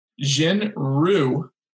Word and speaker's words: Jin Ryu: It's Jin Ryu that's how you say it, Hyunjin Ryu, who's mm-hmm.